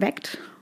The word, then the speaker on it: VACT: Und um ihn zu finden, ähm, könnt ihr V-A-C-T, ausgesprochen weckt, mhm.